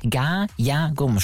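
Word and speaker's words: Ga-ya-gum (가야금): Ga-ya-gum spricht man es aus, diese Harfe. Zumindest mit meinem harten deutschen Akzenten wahrscheinlich.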